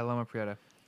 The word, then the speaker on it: Loma Prieta: Talking a little bit about Loma, Continuum from, am I saying this right? Loma Prieta? – Yeah, Loma Prieta.